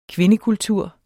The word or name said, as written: kvindekultur